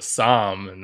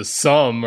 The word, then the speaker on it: Somme: I don't know, but I don't know if you can relate to them that well, since it is actually pronounced Verdun and the Somme, not Verdun and the Somme or whatever the hell you just said.